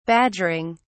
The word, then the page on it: badgering: British English